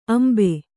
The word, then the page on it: ಅಂಬು: Kannada